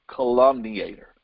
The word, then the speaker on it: calumniator: Another word definition for this word whisperer is a detractor, D-E-T-R-A-C-T-O-R, okay? Or a calumniator, it's pronounced calumniator, I'm sorry. Calumniator, C-A-L-U-M-N-I-A-T-O-R, okay?